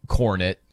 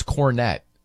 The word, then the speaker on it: Kornet: So they had the Green Kornet, which is lame, right? Because you don't even pronounce it like that. It's Kornet.